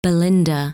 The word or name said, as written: Belinda